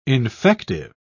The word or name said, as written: infective